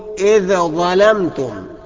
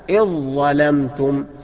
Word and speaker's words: إذ ظلمتم: كذلك إذ ظلمتم، أوعى تقول إذا ظلمتم، خطأ. الصواب إذ ظلمتم بإدغام الذال في الظاء.